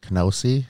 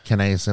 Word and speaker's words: kinase: they'd have less creatine kinase. Is that how you say it? – Kinase in their bloodstream, which is what is released in your bloodstream after muscle is broken down.